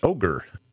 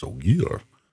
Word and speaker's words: Ogier: He's read about them in books, and he's like, oh, it's pronounced ogre. Loyal's like, no, it is Ogier.